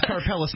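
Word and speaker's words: Karpelès: By the way, I believe his name is Mark Karpeles. – Okay. It's Karpeles now, soldier. – No wonder. I'm like, oh, he sounds Italian. No, it's like, no, that's not how you pronounce his name at all. I was thinking it was spelled like C-A-R-P-L-L-P-E-L-L-I-S.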